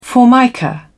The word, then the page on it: formica: British English